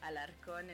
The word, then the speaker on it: Alarcon: So, Alarcon, and you did it perfectly.